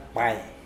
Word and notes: pwai: Mokilese

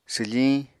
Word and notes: swfiñ: apatani

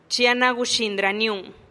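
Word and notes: chi a na guxinih raniun: Teotitlán del Valle Zapotec

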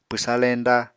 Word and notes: pwsa leñda: Apatani

